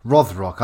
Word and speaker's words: Rothrock: So is it Rothrock or Rothrock? – Rothrock? What do I say? Roth. – You say Rothrock.